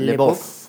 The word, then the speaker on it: LaBeouf: Bob. – Más o menos, Lebov.